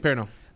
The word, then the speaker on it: Perrineau: But then Harold Perrineau, I'd never say his name right. – Perrineau. He's awesome.